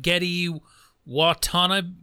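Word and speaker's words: Gedde Watanabe: I can never pronounce his name, but Getty Watanabe. – That's it. Just like Ken Watanabe.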